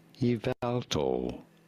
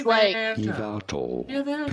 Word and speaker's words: Yveltal: Yveltal. You do want to stress on the second syllable. – That guy's voice, Yveltal.